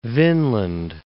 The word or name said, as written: Vinland